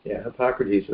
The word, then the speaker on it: Hippocrates: He was of the lineage of the great Greek physician Hippocrates. Is that how you say his name? – Yeah, Hippocrates.